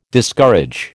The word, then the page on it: Discourage: American English